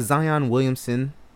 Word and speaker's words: Zion Williamson: Duke star Zion Williamson, I'm saying his name right, okay, yeah.